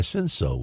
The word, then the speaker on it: Cinsault: But I was surprised to see that they vinify Cinsault, which Cinsault is it's spelled C-I-N-S-A-U-L-T.